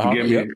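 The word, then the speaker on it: haka: Is it the Hawka dance? – Yep, it's the Hawka.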